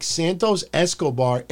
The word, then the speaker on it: Santos Escobar: I have to see, when I say his name, I can't just say Santos Escobar. It's like when someone that's not Italian is like, can I have a little bit of that ricotta cheese? Or what do they say? Can I have a little bit of soprasada? Oh, you got to say soprasada, ricotta, soprasada, mozzarella, pepperoni. So when I say Santos Escobar, I can't be like Santos Escobar entered the ring.